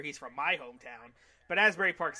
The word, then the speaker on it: Asbury Park: Joey Janela defeating Nick Gage um, at the in a As Asbury Park. Am I pronouncing it correctly? – Asbury, my Jersey friend? Asbury Park, baby.